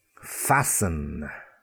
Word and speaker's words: fasten: Fasten. To fasten your coat. It's a T in the middle, F-A-S-T-E-N, but the T is silent. Fasten. Listen. I like to listen to music. Listen. L-I-S-T-E-N.